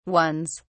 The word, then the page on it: ones: British English